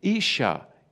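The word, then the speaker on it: Isha (אישה): And wife is spelt Aleph Sheen Hey Isha.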